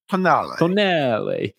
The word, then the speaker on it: Tonale: Speak your best Italian. Tonale.